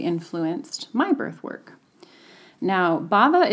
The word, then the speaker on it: Bhava: It is a Sanskrit word that is, and again, I'll spell it for you, B-H-A-V-A. So that H is kind of silent.